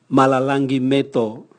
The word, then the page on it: malalangi meto: Raga